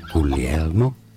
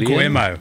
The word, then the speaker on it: Guglielmo: Guglielmo Marconi. Is how it's said in Italian. Guglielmo. – You said Guillermo.